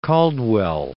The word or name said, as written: Caldwell